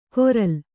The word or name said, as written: ಕೊರಲ್